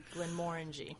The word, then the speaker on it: Glenmorangie: Think of it this way. It rhymes with orangey, Glenmorangie.